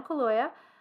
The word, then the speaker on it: Kaluuya: I haven't ever heard his name pronounced out loud before, but it's Daniel Kaluuya, Allison Williams, Bradley Whitford, my handwriting is good, and Catherine Keener.